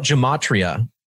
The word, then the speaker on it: gematria: So when we're talking about gematria, this is geometry within the language. Some people pronounce it gematria or gematria. I like the hard G because it's geometry in language.